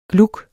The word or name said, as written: GLUGGERNES